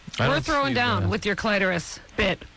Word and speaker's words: clitoris: Listen, when you look at a dictionary, sometimes it will have a couple of pronunciations listed. – Yes. – The first is always preferred. – Oh, interesting. – Nice. – And in the New World, Webster's New World Dictionary. – Clitoris is the first one. – Clitoris is the first, but here's the thing that Kathy might be interested in, because she mentioned it earlier. – And I have one. – The secondary pronunciation is clitoris.